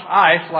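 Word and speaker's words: eis: That's a little Greek preposition called, it's pronounced ice, like ice, an ice cube, only it's a word for a Greek preposition.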